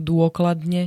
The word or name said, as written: dôkladne